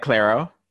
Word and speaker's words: Clairo: So I've actually been listening, it's not like a Halloween song or anything like that, but I've been like really into Clairo recently. I don't know if I'm pronouncing that right. – Like Clairo? – Yeah, there you go.